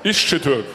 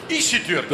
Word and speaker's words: Iscitürk: Wie spricht man das aus? – Ischitürk. – Ischitürk.